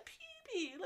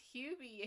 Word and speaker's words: Hubie: She's like, you can't even say his name right. It's Hubie, and he goes, I'm sorry. I was Peeby.